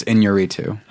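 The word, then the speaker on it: Iñárritu: Inarritu. – I believe it's Inarritu. – Okay. – It's got the little, squiggle over the end.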